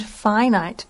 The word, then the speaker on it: finite: Yeah, we said infinite and finite, very different on the I sound.